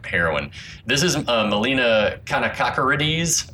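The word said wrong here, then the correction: Kanakaredes: She says it's pronounced Kanakarides. It's like a can of Karides.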